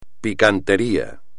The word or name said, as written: picantería